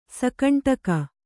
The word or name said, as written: ಸಕಂಟಕ